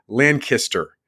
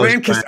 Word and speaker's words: Lancaster: It's Lancaster. Lancaster. Did I say that right? – Lancaster, almost. Lancaster.